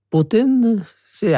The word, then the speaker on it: Putin: Uttaler Putin si eller Putin? – Putin, sier jeg, men eh, man kan si hva man vil.